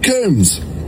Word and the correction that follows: Combs: Combs, apparently. I need you to jump in here and settle this because I am being told that I pronounced your name wrong. And it's Luke Combs, not Luke Coombs.